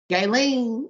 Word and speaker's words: Gaylene: That's how you say her name? – Gaylene, yeah. – Nigga, I've been trying to figure out that fucking name for I don't know how. I ain't put a hard G on that shit at all. That shit was some G-lings and some J-lane.